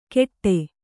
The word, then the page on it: ಕೆಟ್ಟೆ: keṭṭe